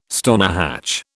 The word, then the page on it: stonehatch: British English